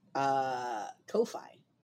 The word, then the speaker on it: Ko-fi: Ko-fi. – Kadashvi. – Kadashvi. Yes. K-O-F-I is where we're going.